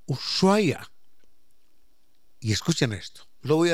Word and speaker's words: Ushuaia: Y entonces dijo, si quiere pronunciar bien, ponga sus labios, como si fuera a besar. Ushuaia.